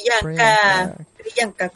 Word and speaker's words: Priyanka: ¿Priyanka se pronuncia? – Sí. Priyanka, como Priyanka Chopra.